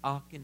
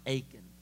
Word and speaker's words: Aachen: World War II documentaries, and I was reading this story about Aachen, this little city, and I know it's spelled with two A's, folks, but I'm pretty sure it's called Aachen, not Aiken.